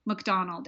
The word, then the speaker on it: McDonald: And my name is spelled like a chemical, Roxanne, R-O-X-A-N, McDonald, M-C-D-O-N-A-L-D.